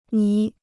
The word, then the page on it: 泥: ní